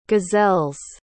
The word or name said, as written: gazelles